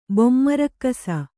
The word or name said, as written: ಬೊಮ್ಮರಕ್ಕಸ